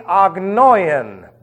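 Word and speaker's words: Agnoian (ἄγνοιαν): Through the agnoian, I'll spell that for you, A-G. It's really not a G, but I'll say it's a G. N-O-I-A-N. Agnoian.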